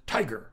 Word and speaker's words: TIGHAR: And we'll use the acronym T-I-G-H-A-R and pronounce it Tiger.